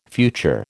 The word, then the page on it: future: American English